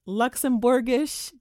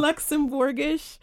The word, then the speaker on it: Luxembourgish: She speaks German, French, Luxembourgish. Did I say that right? – Yeah, that's close enough. – Luxembourgish, and is the self-proclaimed Afropean American.